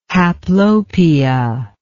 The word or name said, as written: haplopia